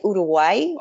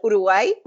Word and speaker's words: Uruguay: Well, we say Uruguay with the accent on the last. – Oh, that's lovely. Say it again. – Uruguay.